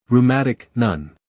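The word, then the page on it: rheumatic: uk ruˈmætɪk; en-us ruˈmætɪk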